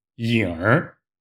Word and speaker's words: 影儿: 另外，如果你想说的更地道的话，一定不要忘记在“ 影” 后边加一个儿化音，“ 影儿” 没影了，没影了。